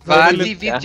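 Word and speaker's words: Vatividya: Ah, Vati Vidya.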